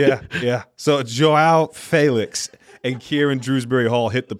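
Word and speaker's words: João Félix: It's not Joe Felix, by the way. It's Joao Felix. So, my fault. Joao Felix.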